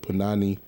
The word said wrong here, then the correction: Panini: I said that wrong, forget y'all. Mustard with Ballin and Young Thug. – Panani, right? – Panini.